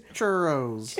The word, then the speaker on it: churros: Why are they saying that choro is a bad thing? Isn't it just that? – Isn't it churros? Like, isn't it good yummy stuff? Sugar? – You're pronouncing it wrong. It's churros.